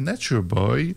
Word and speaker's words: Nature Boy: C'est paru donc à la fin de la saison dernière, Nature Boy. Ouais. – Faut prononcer à l'anglaise, d'ailleurs, il avait précisé.